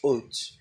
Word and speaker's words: Ot: The word for signs there is Ot, spelled Aleph Vav Tav.